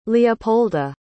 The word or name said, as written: leopolda